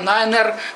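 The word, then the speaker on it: niner: Nine, это nine-er, надо произносить. Как написано, так и говорить.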